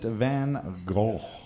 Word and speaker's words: van Gogh: Question number four, Sunflowers was a painting from 1888 painted by Vincent van Gogh. – Van Gogh. I think is how it's pronounced. – Thank you. – You gotta hack up a little bit.